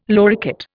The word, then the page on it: loricate: Indian